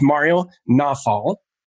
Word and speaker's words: Mario Nawfal: We're going to be talking about culture today, and we're going to have a good conversation, pretty straightforward, with Mario Nawfal.